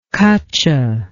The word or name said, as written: cutcha